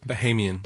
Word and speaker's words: Bahamian: Bahamian? Is that how you say it? – Bahamian. – Bahamian. See, I knew I'd get it wrong. The government of the Bahamas, but the Bahamian government.